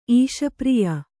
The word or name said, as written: ಈಶಪ್ರಿಯ